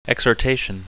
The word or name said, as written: exhortation